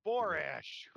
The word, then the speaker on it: Borash: How do you say it? – Borash.